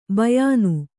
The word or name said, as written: ಬಾಯಿನ